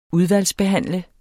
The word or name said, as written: udvalgsbehandle